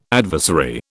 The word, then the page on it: adversary: British English